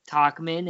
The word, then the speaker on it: Tauchman: Also, do you know that Mike Tauchman's name is pronounced Tauchman and it rhymes with Cockman?